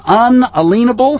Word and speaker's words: unalienable: All men are created equal, and they are endowed by their Creator with certain unalienable, that's the correct pronunciation,